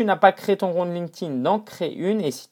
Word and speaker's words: LinkedIn: D'accord Bon, ça se prononce LinkedIn, pas LinkedIn, c'est de l'anglais.